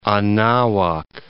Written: Anahuac

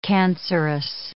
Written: cancerous

